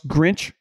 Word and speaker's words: Grinch: But he believes in this defensive coordinator, Alex Grinch, is how you say his last name, who you would say, based on Oklahoma and based on this year, is pretty questionable.